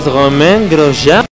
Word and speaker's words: Romain Grosjean: Ah, el nuevo piloto de la Renault es el piloto francés Romain Grosjean, que va en vez de Piquet Jr., que no le rindió ahí a la escudería francesa.